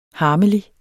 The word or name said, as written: harmelig